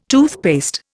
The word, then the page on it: toothpaste: Indian